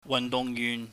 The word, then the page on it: wahnduhngyuhn: Cantonese